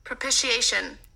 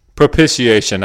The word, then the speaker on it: propitiation: Propitiation. – Propitiation. I was way off. It's Propitiation.